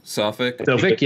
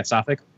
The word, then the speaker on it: Suffolk: I was at a Suffolk, however they pronounce it. I never pronounce it right. – Suffolk, yeah, Suffolk.